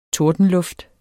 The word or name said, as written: tordenluft